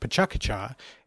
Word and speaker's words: Pecha Kucha: Pecha Kucha, correctly pronounced Pecha Kucha, is a Japanese word that means chit-chat.